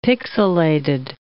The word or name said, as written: pixilated